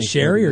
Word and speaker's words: Shari: Does it even is it Sherry or Cherry? – Cherry's Berries. – JV, is it spelled with an S or a C? – S. – I'm way better than you at this. – S-H-A-R-I.